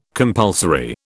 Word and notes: compulsory: British English

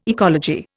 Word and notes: ecology: Indian

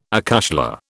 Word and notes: acushla: British English

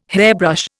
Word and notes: hairbrush: Indian

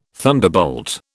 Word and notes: thunderbolt: British English

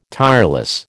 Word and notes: tireless: American English

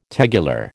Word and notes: tegular: American English

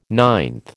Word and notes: ninth: American English

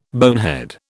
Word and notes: bonehead: British English

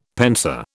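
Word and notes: pensée: British English

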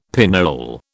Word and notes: pinole: British English

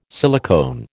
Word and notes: silicone: American English

